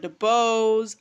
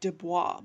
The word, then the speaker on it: DeBose: And I love Ariana DeBose. I always say her name like Dubois, but it's DeBose. I don't know why I say Dubois. Because there's no, like, asterisks on top of her name.